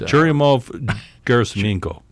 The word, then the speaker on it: Churyumov-Gerasimenko: It's Churyumov-Gerasimenko.